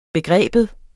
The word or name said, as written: begreb